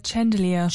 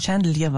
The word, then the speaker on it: Chandelier: Chandelier. – Chandelier war es, genau, richtig.